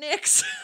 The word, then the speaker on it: NYX: NYX Cosmetics, but so those are all capitalized, but maybe there's, maybe it's NYX. – I think you do pronounce it NYX.